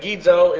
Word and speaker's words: engizo: And is Kai here, and Gizo is the verb, E-N-G-I-Z-O. It's actually, again, two gammas, but in the rules of translation and transliteration, the first one's pronounced as an N when you have two hard consonants like that in the Greek.